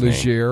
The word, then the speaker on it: Legere: How do you say the guy's Legere.